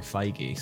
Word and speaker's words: Feige: For a long time, I called him Fage. – I've always thought it was Feige, but I've never been sure. – The only pronunciation I've ever heard is actually Feige, so... I think you're right.